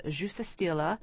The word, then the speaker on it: Juste Stille: This is Juste Stille. How's my pronunciation, Elizabeth? Is it okay? – Not bad.